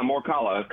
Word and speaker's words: Morikawa: Yeah, Morikawa.